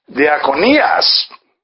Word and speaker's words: diakonias: And the word ministry here is from the Greek noun, diakonias. And it is spelled D-I-A-K-O-N-I-A-S.